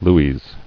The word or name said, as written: Lues